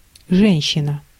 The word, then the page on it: женщина: [ˈʐɛnʲ.ɕːɪ.nə]; ženštšina